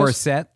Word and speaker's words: Morissette: Morissette.